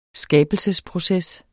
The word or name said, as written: skabelsesproces